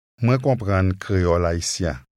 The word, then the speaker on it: Haïtien: It has a nasal sound to it. Try repeating it after the speaker. – Haïtien.